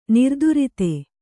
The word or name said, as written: ನಿರ್ದುರಿತ